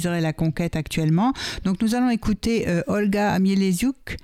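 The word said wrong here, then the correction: Mieleszczuk: Je prononce bien. – Non, mais il est chouque. – Mieleschouk, voilà.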